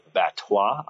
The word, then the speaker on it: Batsois: For the fifth annual event, we're presented by Batsois. I have learned from our friend Mario Mercado that the S is silent. It's a French word.